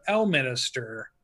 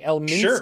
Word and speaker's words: Elminster: I didn't know the names of Mordekainen, barely knew Vecna, maybe had heard of, you know, a few of the others, but Elminster was You're even saying it wrong, so that's funny. Elminster.